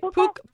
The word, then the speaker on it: Puka: The only thing is, you know, and I'm going to pronounce his name wrong, Puka? – No, that's right. Puka.